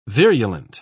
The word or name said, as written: virulent